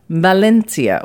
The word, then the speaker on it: Valencia: it is really a Valencian dish coming from Valencia, or Valencia, as is pronounced in Spain.